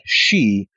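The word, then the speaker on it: she (し): And that's interesting because in Japanese, the number 4 is pronounced something like she, which is the same as their word for death.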